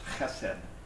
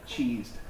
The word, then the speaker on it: chesed: It's chesed. Chesed. It's spelled like cheesed, C-H-E-S-E-D, but it's chesed.